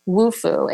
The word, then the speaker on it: Wufoo: So one of my favorite tools for your list here is WooFoo. It's spelled W-U-F-O-O, and it's basically an online form builder.